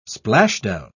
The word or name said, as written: splashdown